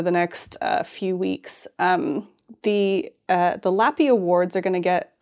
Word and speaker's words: LLAPY: they've announced this week the inaugural Lappy Awards, which Marina very helpfully tells me that's how it's pronounced, L-L-A-P-Y Awards, and it's just all about Star Trek stuff.